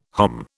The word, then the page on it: hom: British English